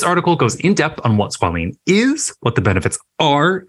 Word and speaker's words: squalene: Okay, first, before we get there, you may notice that Jeffrey pronounced squalene and squalene differently. – Yes. – Well, our bodies produce a lipid called squalene with an E to moisturize and rejuvenate our skin. By the time you're 20s, 30s, and 40s like me, we're toast. It begins to decrease in our skin and it looks drier and damaged. Okay. That is squalene.